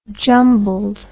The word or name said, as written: jumbled